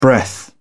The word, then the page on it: breath: British English